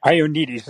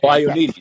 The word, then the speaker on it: Ioannidis: Ioannidis, how do you pronounce his last name? Ioannidis. – Ioannidis is how I've always heard. Oh, Ioannidis, right. – Pretty sure that's how I've heard him pronounce his last name.